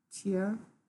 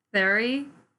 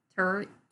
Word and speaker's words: Thury: It's like T-H-Y-R. – Tier. Why do I say it like I'm a... – Okay. So we couldn't figure out how to pronounce this dude's name. We just looked it up. But it is the American way that you could guess to pronounce it, because it looks like this, is Héricart de Thury.